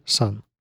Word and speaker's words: Sunn O))): получается, это sun O? – Нет, читается именно как sun.